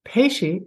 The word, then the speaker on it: Pesce: And my first name is Teresa, spelled without the H, and Peshi is five letters, PESCE. If you type in advice, Teresa Peshi, it goes directly to my page, and there's a sample chapter, so you can see.